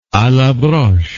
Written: a la broche

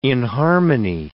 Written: inharmony

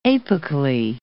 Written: apically

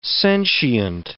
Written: sentient

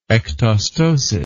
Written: ectosteally

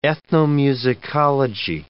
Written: ethnomusicology

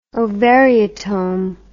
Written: ovariotome

